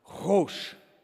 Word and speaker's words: hosh: Well, here's why that word hastily in the original Hebrew is pronounced hosh. Hosh. That is a Hebrew word.